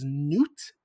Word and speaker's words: Nut: So according to Egyptian mythology, Nut, by the way, this website that I looked it up on wants me to pronounce it as Newt, and I'm like, you're joking with me.